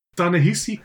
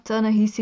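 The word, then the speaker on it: Ta-Nehisi: Tanehisi of Tanehisi Coats. – Die eerste was wel goed hoor. – Ehm, ja, Tanehisi.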